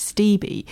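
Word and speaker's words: Stibbe: So he's the only person who's talked to me about the pronunciation and said that it's Stevie.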